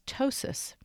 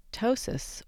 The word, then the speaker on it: ptosis: In the plastic surgery world, the official name for settled, or I hate to say it, sagging, of the breasts is ptosis. Now that word starts with a silent letter P. Ptosis, or the adjective form ptotic, can actually be used to describe anything in the body that sags, even an eyelid.